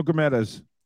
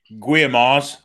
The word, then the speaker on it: Guimarães: Say it the way they do it. – Bruno Guimarães. – Guimarães. I will give. – Guimarães.